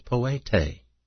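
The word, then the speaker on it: poete (ποιητής): And doer is a word, and I'll spell it for you, P-O-I-E-T-E, P-O-I-E-T-E. And that's pronounced poete. And we get an English word from that, don't we? Poete, and from that comes poet. P-O-E-T.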